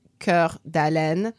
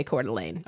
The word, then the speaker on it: Coeur d'Alene: Basically pronounced, like, it's spelled Coeur d'Alene. – Mm-hmm. But Americans would not say that. – No, we say Coeur d'Alene.